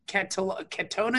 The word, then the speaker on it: Cantona: Cantona, is that how you pronounce his name? – Yeah.